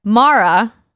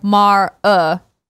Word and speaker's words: Mara: Greg said it wrong. It's Mara. – Mara. – Sort of like Mar-uh.